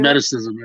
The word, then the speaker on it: Hermeticism: Okay, so Hermeticism, I think is how you say it. – Hermeticism, there you go.